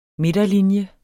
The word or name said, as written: midterlinje